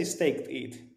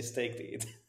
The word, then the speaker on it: stETH: Oh, but and then how do you pronounce Steeth or S-T-E-T-H? – I just say staked ETH. Even when I see the S-T-E-T-H, I just say staked ETH.